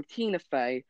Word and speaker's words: Tina Fey: Tina Fey, Steve Whitemere, Eric Jacoba, and Dave Gold, which I've pronounced all of them wrong, of course.